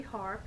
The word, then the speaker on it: Harp: Harp, B-A-R-B-A-R-A, the initial K, H-A-R-P.